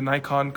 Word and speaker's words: Nikon: You guys remember that close-up picture of the ant that that came up through that Nikon, is it called Nikon, is that how you say it? Yeah, the Nikon competition.